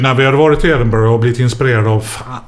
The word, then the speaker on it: Edinburgh: Vi åker ju ibland till eh, Comedy Festival i Edinburgh. – Mm. – Eller EMBRA som man ska uttala. det. Embra. Embra! Welcome to Embra!